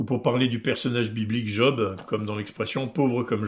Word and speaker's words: job: En fait, pour prononcer le Job qui nous intéresse, il y faut un J glissant sur le J, et dire job, comme on dit jackpot ou jazz, ou alors le prénom Jamel.